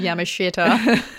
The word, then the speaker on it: Yamashita: That pronunciation was Yamashita.